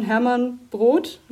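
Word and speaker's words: Hermann Brood: Und da war dann das zumindest als erstes veröffentlichtes Lied aus dem Jahr 1978, äh, Doreen von Hermann Brot. Das richtig ausgesprochen? Ja, ja genau.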